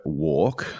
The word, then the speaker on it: WARC: So another stat that you call out in this article is, according to a piece of research by WARC, W-A-R-C, that is how you pronounce it. That's not my accent.